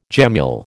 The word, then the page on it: gemmule: American English